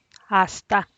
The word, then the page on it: asta: Teotitlán del Valle Zapotec